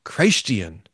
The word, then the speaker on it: Christian: Dr. Schultz says he doesn't pronounce it Christian. He pronounces it Christian. which means that we belong to Christ.